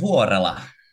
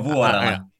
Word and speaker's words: Vuorela: Vuorela in Finnish. Oh boy. – yeah, Niko Vuorela.